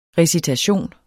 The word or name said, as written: RECITATION